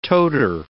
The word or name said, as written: toter